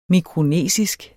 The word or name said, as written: mikronesisk